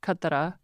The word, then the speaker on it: Katra: So I woke up in Katna. – Just a really quick correction here. Every time we say Katna, we are mispronouncing the name of the town, which is actually Katra, K A T R A.